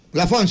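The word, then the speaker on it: Lafont: Aunque este creo que sí se pronuncia así. Lafon. – Lafon, sí, ese es Lafon. – Sí. – O podría ser Lefon. – No, me parece que sí es Lafon.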